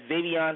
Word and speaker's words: Zaveon: It's spelled Z-A-V-E-O-N. Space J-E, capital Q-U-A-N, and that's Xavion Jaquan.